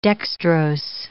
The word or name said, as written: dextrose